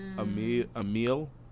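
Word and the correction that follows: Amélie: I don't know how to pronounce Amelie? – This one. It's like Amelie, yeah.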